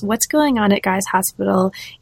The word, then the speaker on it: Guy's Hospital: And so that fieldwork that you bring up in these early chapters of the book is fieldwork that you did at Guy's Hospital. Am I pronouncing that correctly? – That's right, yeah.